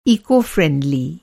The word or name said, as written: इको-फ्रेंडली